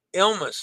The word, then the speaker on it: Ilma: But the Ritz-Carlton Yacht Collection, their CEO, Jim Murin, popped the cork of a 6-liter bottle of Moët and Chandon to signal the start of Yama, is the way I'm guessing this is pronounced. The spelling is... oh, it's I-L-M-A, Ilma's float out. Okay, so Ilma. I thought it was L-L-M-A, and I tried looking that up this morning, and there was no L-L-M-A pronunciation anywhere.